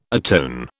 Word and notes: atone: British English

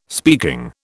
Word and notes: speaking: British English

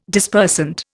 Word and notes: Dispersant: Indian

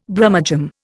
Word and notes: Brummagem: Indian

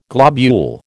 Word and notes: globule: American English

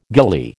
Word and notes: gillie: American English

